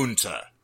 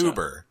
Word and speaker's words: Unter: Unta. It's not Unta. Uber. It's Unta.